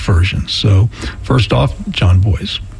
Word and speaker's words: Bois: Next, we're going to hear an artist who was on the Acoustic Peace Club earlier this year, John Boys. It's spelled B-O-I-S, but pronounced like boys and girls.